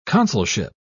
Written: consulship

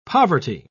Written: poverty

